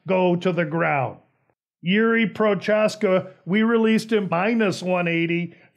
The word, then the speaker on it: Prochazka: And provided Prochazka has space, and I believe he wants his name pronounced Prohaska, so excuse me.